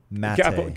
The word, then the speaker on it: Maté: Gabo Maté. – Gabor, G-A-B-O-R. – Got it. – Maté, M-A-T-E.